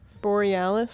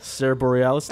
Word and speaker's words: Bareilles: The single itself didn't have Sarah Bareilles. Is that how you pronounce the last name? – Borealis. – Sarah Borealis.